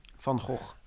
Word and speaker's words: Van Gogh: Van Gogh.